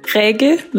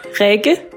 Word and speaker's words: Rikke: Rikke Lindva Sevecke. – One more time. – Rikke Lindva Sevecke.